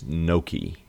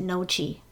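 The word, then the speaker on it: gnocchi: But I have so always pronounced that word, when I see it written, I always pronounce it Nochi. But I know it's Noki okay. from hearing right. people say it. Next one. How do you properly pronounce C-H-I-P-O-T-L-E?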